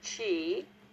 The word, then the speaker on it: QI: It's QI, pronounced chi, and it has C-H-E-E. – Mm-hmm. – The other spelling for chi is C-H-I, mm-hmm.